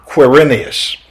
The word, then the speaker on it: Quirinius: But during the birth of Christ, There is one who takes the governorship whose name is Cyrenius, and sometimes pronounced with a Q, or Quirinius.